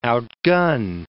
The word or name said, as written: outgun